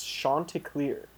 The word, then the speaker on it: Chanticleer: The proper pronunciation is Chanticleer.